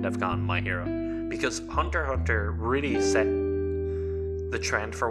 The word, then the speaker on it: Hunter x Hunter: Like, Hunter Hunter. I know the X is silent, so Hunter Hunter is what made My Hero Academia.